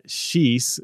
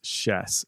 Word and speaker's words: Schiess: I always called her Schiess because that's what it looks like on paper, but it's actually pronounced Schiess.